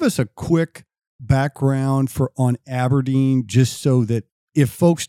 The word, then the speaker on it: abrdn: Aberdeen is abbreviated lowercase A-B-R-D-N, but you pronounce it Aberdeen.